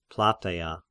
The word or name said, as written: Plataia